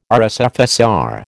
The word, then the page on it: RSFSR: American English